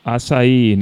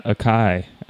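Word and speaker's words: açaí: That, I just learned that it's acai, not acai.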